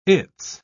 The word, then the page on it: it's: British English